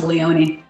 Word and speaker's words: Leone: I just always grew up with Leone.